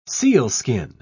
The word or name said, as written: sealskin